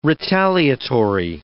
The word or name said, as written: retaliatory